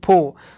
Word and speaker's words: Pau: Das erklärt dann auch, wieso im letzten Formel-3-Rennen, das war der große Preis von Po, also P-A-U geschrieben, ist in Frankreich, deswegen wird es Po ausgesprochen.